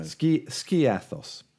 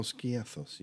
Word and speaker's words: Skiathos: I'm going to Skiopos. I never pronounce it. – Skiopos. – I've been. I don't know whether you've used the weather app on iPhones. – No. – Skiathos. – Oh, Skiathos.